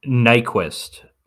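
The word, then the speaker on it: Nyqvist: I can never pronounce his last name, but I've seen him in other things. – Nyquist. – Is that which, it? Yeah. – Yeah, just like Gustav Nyquist, who at least used to play for the Red Wings.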